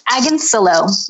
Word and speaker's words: Agoncillo: It's Agansilo, rhymes with pillow.